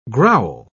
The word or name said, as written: growl